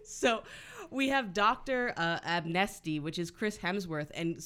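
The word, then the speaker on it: Abnesti: So Dr. Stephen Absenti, am I saying it right? – No. – Abnesty. Abnesty. It's just such a stupid name.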